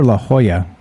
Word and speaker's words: La Jolla: Is it La Jolla or La Jolla? I think it might be La Jolla. It's spelled L-A-J-O-L-L-A.